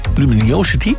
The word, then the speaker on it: Luminosity: Wil jij graag naar het muziekfestival Luminosity? Luminosity, zeg ik dat goed? Ja, op het Zandvoortse strand.